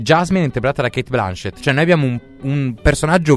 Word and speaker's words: Blanchett: Che ha già preso, tra le altre cose, come non protagonista, ma noi vogliamo Kate Blanchett. O Blanchett, perché pare che si dica come Laurence Olivier con l'accento francese.